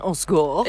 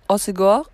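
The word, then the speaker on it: Hossegor: Aber wir sind in Ossegor oder auch französisch ausgesprochen Ossegor. – Ich hab gestern tatsächlich gelernt, die Einheimischen hier sagen Ossegor, aber okay. Vor allem so die Pariser Leute sagen Ossegor.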